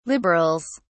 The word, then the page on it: liberals: British English